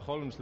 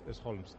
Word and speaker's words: Holmes: Si a Holmes le quitan la L, que en inglés ni la pronuncian, pues ya tienes casa y casa, los dos se llaman casa, ¿no? – Sí, ya es curioso.